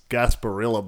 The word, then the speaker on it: Gasparilla: Next is the Bad Boy Mowers Gasparilla Bowl. – You've done that, actually. You pronounced that quite well.